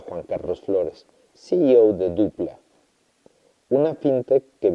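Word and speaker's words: Doopla: Escribe con doble O, Dopla, pero se pronuncia Dupla.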